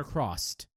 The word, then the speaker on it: Cross-Z: Yeah, Kamen Rider Crossed, which is CROSS-Z.